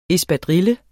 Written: ESPADRILLES

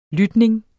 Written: lytning

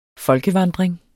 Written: folkevandring

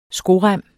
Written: skorem

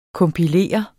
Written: kompilere

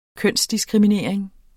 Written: kønsdiskriminering

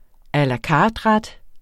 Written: a la carte-ret

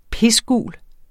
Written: pisgul

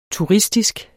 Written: turistisk

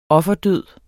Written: offerdød

